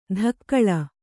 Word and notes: ಧಕ್ಕಳ: dhakkaḷa

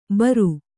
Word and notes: ಭೇರಿ: kannada

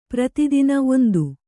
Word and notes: ಪ್ರತಿತುನಿ: prati tuni

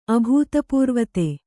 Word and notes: ಅಭೂತಪೂರ್ವತೆ: kannada

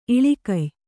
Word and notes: ಇಳಿಕೆಯ್: iḷikey

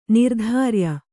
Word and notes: ನಿರ್ಧಾರ್ಯ: nirdhārya

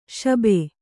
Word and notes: ಶುಭ: Kannada

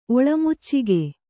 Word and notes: ಒಳಮುಚ್ಚಗ: kannada

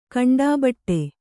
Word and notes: ಗಂಡುಬೀಡು: gaṇḍu bīḍu

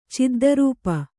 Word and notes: ಚಿದ್ದರೂಪ: kannada

